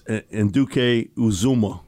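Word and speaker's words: Anudike-Uzomah: I can't pronounce his name, from Kansas State. Felix Amadike Azuma, whatever. The defensive end from Kansas State. – Yeah, Felix Enduke Uzuma.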